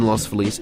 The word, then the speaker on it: Los Feliz: Were you drinking alone in Los Feliz, and why is that place special to you? – Oh, good question. I have drunk alone in Los Feliz. You're saying it correctly.